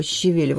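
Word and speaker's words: щавель: Кстати, щевель, слово щевель, вот именно mm-hmm. – ударение на второй слог, оно происходит, произносится, тоже возводит к этому же корню.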